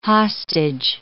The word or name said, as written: hostage